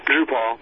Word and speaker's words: Drupal: I don't know how you pronounce it, Drupal or Drupal? – Drupal. – Because it sounds like RuPaul, so you got to say Drupal, right?